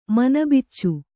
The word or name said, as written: ಮನಬಿಚ್ಚು